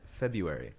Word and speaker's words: February: February. You don't pronounce the R.